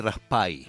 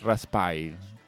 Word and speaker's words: Raspail: Es Fred Raspail. – Raspail. – Como que se escribe Raspail, pero me decía, sí.